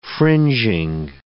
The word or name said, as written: fringing